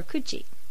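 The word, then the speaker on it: kuchi (口): And the pronunciation is most often either ku or kuchi.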